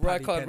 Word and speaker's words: Rijkaard: Make that Rijkaard rhymes with Bicarb.